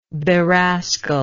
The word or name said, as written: berascal